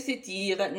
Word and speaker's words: Satir: Enfin, il résulte que Satire, prononcé Satire,